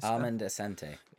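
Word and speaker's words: Armand Assante: How do you pronounce his name? Armand Asante. – Armand Asante. – You say it so much better than I do.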